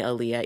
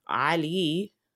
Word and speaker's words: Aaliyah: So Uncle Uber is like, he's like, that's not your name. Your name's Aaliyah. And I was like, well, I know. And he's like, well, then how come you don't say it like that?